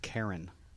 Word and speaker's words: Chiron: I've heard it pronounced Karen, too.